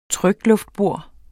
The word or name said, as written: trykluftbor